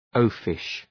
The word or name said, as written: oafish